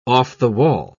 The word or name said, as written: off-the-wall